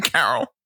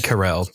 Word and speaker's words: Carell: You said Steve Carroll and not Steve Carell. – I said Carell. – You said Carroll. You said Carroll. – I meant Carell.